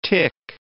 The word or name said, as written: tic